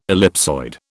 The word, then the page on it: ellipsoid: British English